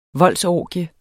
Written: voldsorgie

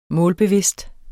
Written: målbevidst

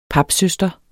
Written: papsøster

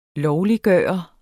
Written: LOVLIGGJORT

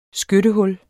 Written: skyttehul

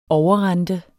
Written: overrente

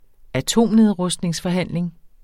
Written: atomnedrustningsforhandling

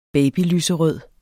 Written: babylyserød

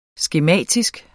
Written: skematisk

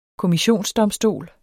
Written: KOMMISSIONSDOMSTOLE

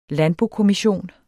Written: landbokommission

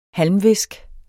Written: halmvisk

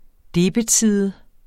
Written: debetside

